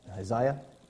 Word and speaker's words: Isaiah: Sorry, Isaiah. That's how you pronounce that. Isaiah.